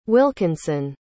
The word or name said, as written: wilkinson